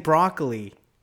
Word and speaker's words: Broccoli: Everyone is saying broccoli and podcasts and all stuff. It's not broccoli. It's Broccoli.